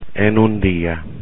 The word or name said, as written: en un día